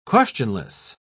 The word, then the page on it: questionless: British English